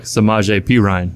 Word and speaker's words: Samaje Perine: Samaj Perion. – I took Samaje Perine.